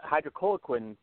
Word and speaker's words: hydroxychloroquine: So take to that to the hydroxychloroquine, forgive me if I'm mispronouncing it. – Hydroxychloroquine. – There you go.